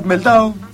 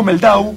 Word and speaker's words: Mehldau: Digo Mehldau porque es de origen alemán y no francés, como acostumbramos a pronunciarlo aquí, de decir Mehldau, ese es su, como se pronuncia su apellido, así me lo han explicado alguna vez.